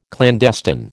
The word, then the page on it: clandestine: American English